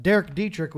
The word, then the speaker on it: Derek Dietrich: I got Derek Dietrich with me.